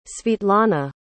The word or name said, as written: svitlana